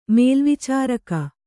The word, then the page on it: ಮೇಲ್ವಿಚಾರಕಿ: mēlvicāraki